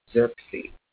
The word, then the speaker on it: Xerxes: So in the New International Version of the Bible, the king's name is pronounced King Xerxes.